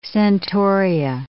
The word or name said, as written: centaurea